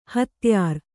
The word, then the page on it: ಹತ್ಯಾರ: hatyār